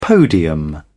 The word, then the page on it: podium: British English